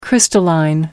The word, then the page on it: crystalline: American English